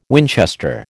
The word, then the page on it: Winchester: American English